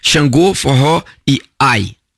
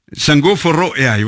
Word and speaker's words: Xangô, Forró e Ai: Xangô, Forró e Ai. – Xangô, Forró e Ai, voilà, vous prononcez comme ça.